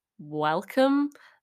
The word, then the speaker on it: Welcome: I don't know his nationality, but it's pronounced Welcome, like, what can I see except You're welcome. Why? Yes, I am welcome. You're welcome. Yeah, I am welcome. You know what I mean? His name is Welcome.